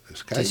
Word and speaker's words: Skype: lo hace por Skype, lo digo bien, ¿no? – Skype. Sí, sí, Skype.